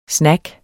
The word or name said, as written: snack